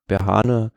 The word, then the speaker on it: Berhane: Also Situation, Fahrer A war Fahrer Nummer Berhane, habe ich das richtig ausgesprochen? Berhane? – Nathanael Berhane, ja, aus der Dimension Data Mannschaft.